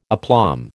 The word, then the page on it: aplomb: American English